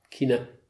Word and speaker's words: Kinder: ohne D, ne? – Ja, Kine.